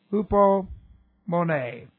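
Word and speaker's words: hupomone (ὑπομονή): But here we have H-U-P-O-M-O-N, long E, hupomone.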